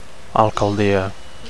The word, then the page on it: alcaldia: Catalan